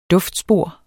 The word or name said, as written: DUFTSPOR